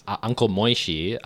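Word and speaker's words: Moishy: Does the first part of his name rhyme with Runkle, and the second part of his name rhyme with Oishi? – Yes, Uncle Moishi, a very popular Jewish children's entertainer, who I grew up listening to.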